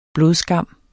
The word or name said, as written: blodskam